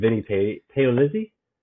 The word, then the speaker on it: Vinnie Paolizzi: Let's welcome in, I'm going to try not to screw up his name because I told him I wouldn't, welcome in Vinnie Paolizzi. Did I say that right? – Perfect. You said every letter. That's how you get that one right.